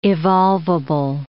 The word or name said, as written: evolvable